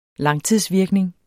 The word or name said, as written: langtidsvirkning